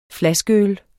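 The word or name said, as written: flaskeøl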